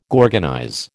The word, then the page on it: gorgonize: American English